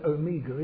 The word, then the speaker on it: Omega: The English use of the word Omega is not what you should read when you're reading the scripture. It's a long O, it's Omega, as in megaphone, the great O.